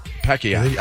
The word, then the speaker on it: Pacquiao: I say it the way it's supposed to be said. – Right. – Pacquiao. – That's how they say it. Pacquiao.